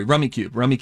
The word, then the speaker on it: Rummikub: With Rummy Cub, R-U-M-M-I-K-U-B. – Oh, or no, I'm sorry, Rummy Cube. It's pronounced Rummy Cube, but it's spelled Rummy Cub.